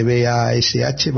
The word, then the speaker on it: Bash: que se escribe BASH, Bash, el intérprete de comandos, de línea de comandos.